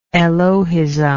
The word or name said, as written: elohism